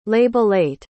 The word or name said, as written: labellate